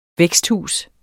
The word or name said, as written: væksthus